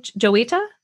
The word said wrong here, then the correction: Jowita: Jowita. – Jowita.